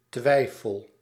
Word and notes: twijfel: /twɛɪ.fəl/

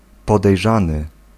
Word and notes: podejrzany: /pɔd̪ɛjˈʐan̪ɨ/

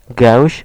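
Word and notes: gęś: [ˈɡɛ̃ɕ]; Polish

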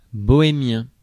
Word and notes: bohémien: [bɔ.e.mjɛ̃]

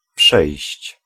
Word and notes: dépasser: France (Paris); [de.pa.se]

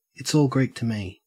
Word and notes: it's all Greek to me: /ɪts ˌɔːl ˈɡɹiːk tə ˌmiː/

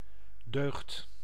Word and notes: vertu: France; [vɛʁ.ty]

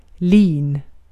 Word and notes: lin: /liːn/

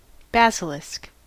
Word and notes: basilisk: /ˈbæs.ɪ.lɪsk/; [ˈbæs.ɪ.lɪsk]

